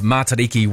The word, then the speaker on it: Matariki: It's a short sound, mata, riki. Don't elongate the sound.